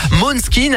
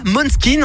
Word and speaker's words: Måneskin: Måneskin en danois veut dire clair de lune. Et il faut savoir aussi, petite subtilité, c'est qu'en danois, eh ben, le A se prononce O.